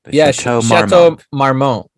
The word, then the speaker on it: Chateau Marmont: Chateau Marmont? Marmont? I can't even fucking pronounce. – Chateau Marmont.